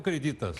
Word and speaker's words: Creditas: Eh, como é que eu pronuncio corretamente? É crédito ou creditas? Creditas. Creditas, ok.